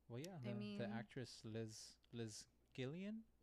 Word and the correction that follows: Gillies: is that how you pronounce it? – Liz Gillies.